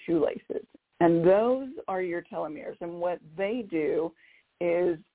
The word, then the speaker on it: telomeres: Now, there's another patch which I love, and if any of our callers have or listeners have looked into telomeres, or telomeres is how some people pronounce it, T-E-L-O-M-E-R-E.